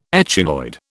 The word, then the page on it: echinoid: British English